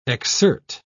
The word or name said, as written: exsert